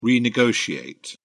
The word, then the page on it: renegotiate: British English